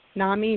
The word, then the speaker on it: NAMI: I'd never actually heard of, is it pronounced NAMI, N-A-M-I? NAMI, NAMI? – It is pronounced NAMI, N-A-M-I.